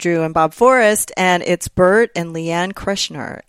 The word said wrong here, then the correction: Kreischer: It's Bert and Leanne Kreischer.